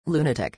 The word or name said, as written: lunatic